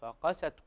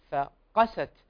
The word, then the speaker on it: فقست: فمثلا هنا النطق الصحيح لمثل هذه الكلمة فقست عندنا حرف الفاء حرف هنا مرقق يتلوه القاف، ومن حروف التفخيم ويفخم باتفاق، فمرقق فمفخم فقست.